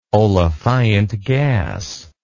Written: olefiant gas

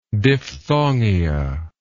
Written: diphthongia

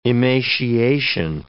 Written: emaciation

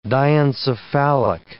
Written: diencephalic

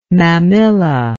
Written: mammilla

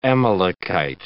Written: Amalekite

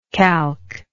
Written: calc

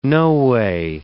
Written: noway

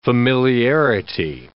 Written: familiarity

